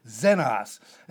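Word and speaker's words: xenos (ξένος): And this is the word xenos, Z-E-N-O-S, or the Greek word letter X. So it would be X, like the X in xylophone. The X is silent, and we pronounce it as xenos.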